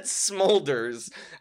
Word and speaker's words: Smulders: It's Smulders. – No, that's how it's spelled. – Okay. – But it's said Smolders.